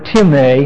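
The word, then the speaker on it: time (τιμή): This is the word time in Greek. T-I-M-E, long E.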